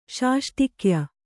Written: ಷಷ್ಟಿಕ್ಯ

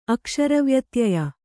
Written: ಅಕ್ಷರವ್ಯತ್ಯಯ

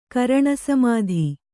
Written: ಕರಣಸಮಾಧಿ

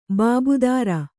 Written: ಬಾಬುದಾರ

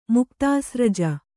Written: ಮುಕ್ತಾಸ್ರಜ

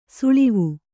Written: ಸಳವ

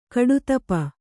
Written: ಕಡುತಪ

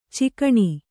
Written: ಚುಕ್ಕಣ

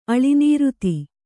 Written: ಅಳಿನೀರುತಿ

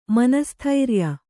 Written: ಮನಸ್ಥೈರ್ಯ

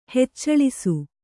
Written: ಹೆಚ್ಚಳಿಸು

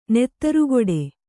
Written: ನೆತ್ತರುಗೊಡೆ

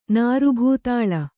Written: ನಾರುಭೂತಾಳ

